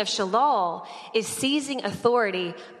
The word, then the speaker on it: shalal: It's spelled S-H-A-L-A-L, shalal.